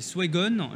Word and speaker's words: Swegon: Vous êtes euh, directeur commercial chez Swegon. Je l'ai bien prononcé Tout à fait, c'est exactement ça.